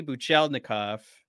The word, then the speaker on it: Buchelnikov: Are we waiting on Buchelnikov? Buchelnikov? I think I've skipped a syllable.